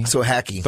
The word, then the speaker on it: Sohacki: Sohacki.